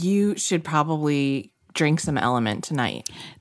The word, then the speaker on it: LMNT: LMNT, but it's pronounced element.